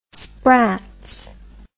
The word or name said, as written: spratts